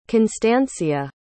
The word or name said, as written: constancia